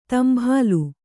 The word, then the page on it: ತಂಭಾಲು: Kannada